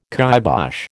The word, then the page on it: kybosh: American English